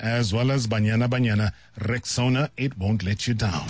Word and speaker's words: Rexona: It is pronounced Rexona. Simple, strong, and easy to remember.